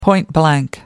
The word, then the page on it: point-blank: American English